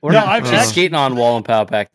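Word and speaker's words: Wallenpaupack: You're saying it like Lake Wallapanaka or whatever. – Yeah. – Like, Wallen Paw Pack. – Wallen Paw Pack. Or as Aaron says, Walmart Snack Pack.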